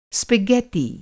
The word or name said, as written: स्पगॅत्ती